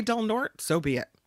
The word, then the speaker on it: Del Norte: Is that how the locals pronounce it? – It's the correct pronunciation. – No, but it's Spanish. It would be Norte. – No, it isn't. As a matter of fact, if you're talking about the BART station in the East Bay, it's Del Norte. But if you're talking about the county, right, they pronounce it Del Norte.